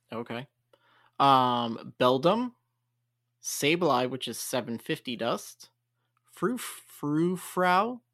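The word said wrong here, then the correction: Furfrou: Did I say that right? – Furfrau.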